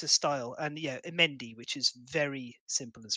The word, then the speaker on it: Mehndi: It's a Mendy. It's actually misspelt in Kingdom Death. It should be M-E-H-N-D-I, but it's spelled M-H-E-N-D-I.